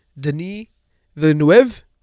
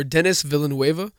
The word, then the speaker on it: Villeneuve: It's, the director is a Canadian, Denis Villeneuve, or Denis Villeneuve, depending how you want to pronounce that shit.